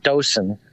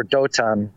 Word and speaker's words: Dothan: I mean, in Hebrew, it would be, you know, it would be you know, most people pronounce it Dosan, or, you know, or Dotan, but okay.